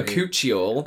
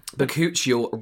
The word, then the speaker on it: Bakuchiol: Bak choy. – Bakuchiol. – Oh. – Bakuchiol Retinol Alternative Smoothing Serum.